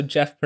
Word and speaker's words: Gef: Also, Jeff pronounced G-E-F. Spelled G-E-F.